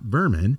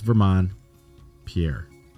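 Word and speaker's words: Vermon Pierre: Well, ladies and gentlemen, that concludes this week's episode with Vermont Pierre, not Berman. We mispronounced it, but he corrected us. But he's not a vermin. It's Vermont Pierre.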